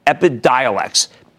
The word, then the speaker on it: Epidiolex: I'm going to pronounce I, I always screw this one up. Epidiolex.